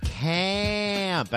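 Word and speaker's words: CAAMP: That was Taylor Meyer and Evan Westfall of CAMP. And I say it like that because it's C-A-A-M-P.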